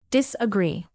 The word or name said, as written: disagree